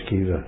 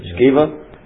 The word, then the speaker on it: Sceva: Sceva. – Sceva.